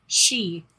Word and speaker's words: sidhe: The Danann eventually evolved into the she, That is spelled S-I-D-H-E. – I wouldn't say it like that. – Nope, I looked it up because I was like, how's that pronounced? It's pronounced she.